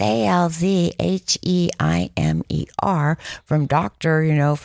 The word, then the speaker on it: Alzheimer: Alzheimer. – How do you pronounce it? Alzheimer. – But most people say Alzheimer's, and that's fine. That's fine if you want to pronounce it that way.